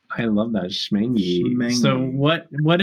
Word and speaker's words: Schmengie: Schmangy. – Schmangy. It's spelled Schmangy. S-C-H-M-E-N-G-I-E. It has a C in it.